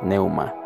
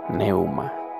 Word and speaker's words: pneuma: Hay una palabra que me impactó mucho hace un tiempo que es neuma, neuma, neuma, neuma. Y la otra es Zoe. Neuma se escribe con P-N-E-U-M-A. Neuma. Soplo de vida. Que también significa espíritu. Y Zoe, eh, Z-O-E, que es calidad calidad de vida.